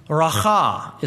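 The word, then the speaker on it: Raca: I've got a long vowel sign over the A, so in English this transliterates as Reka. I looked it up in the concordance, and the Hebrew it is Racha, is the way it is actually pronounced.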